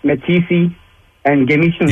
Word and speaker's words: Matisse: Matisse? – Matisse.